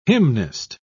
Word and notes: hymnist: British English

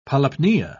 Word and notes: polypnea: British English

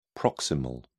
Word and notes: proximal: British English

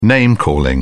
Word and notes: name calling: British English

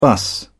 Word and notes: bus: British English

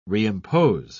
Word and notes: reimpose: British English

